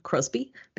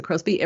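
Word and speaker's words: Crosbie: Crosby, because somebody else had, you know, Lisa Crosby. Everywhere else on the socials, you'll find me on LinkedIn. I'm pretty easy to find by my name. Just spell it Crosby with an IE on the end.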